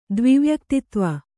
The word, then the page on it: ದ್ವಿವ್ಯಕ್ತಿತ್ವ: dvi vyaktritva